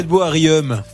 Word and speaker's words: Riom: Riom.